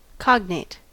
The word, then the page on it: cognate: American English